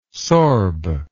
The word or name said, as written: sorb